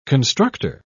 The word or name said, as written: constructor